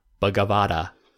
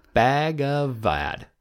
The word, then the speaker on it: Bhagavad: The I'm gonna murder this or butcher this, Bhagavad. – Ooh. – Bhagavad.